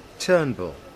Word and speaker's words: Turnbull: Turnbull. – Mm-hmm. How do you spell that, please? – T-U-R-N-B-U-L-L.